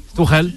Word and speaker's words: Tuchel: Amoureux du beau jeu de Villas-Boas ou de Thomas Tuchel. Eh oui, va savoir en tout cas. À fond derrière nos clubs français. – Thomas comment? – Tuchel.